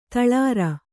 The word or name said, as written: ತಳಿರು